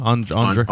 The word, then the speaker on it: Andrzej: Andre. Yeah. – Andre Zulowski's Possession.